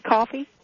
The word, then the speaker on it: Coffey: My name is Coffee, and that coffee is spelled C-O-F-F-E-Y.